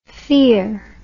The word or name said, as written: fier